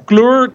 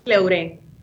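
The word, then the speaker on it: Cleüre: Primero que nada, la pronunciación de tu empresa, Clur, Cleure, ¿cómo se debe pronunciar? – Cleure. Cleure. La diéresis en la última E es como si fuera el acento.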